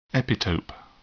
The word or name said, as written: epitope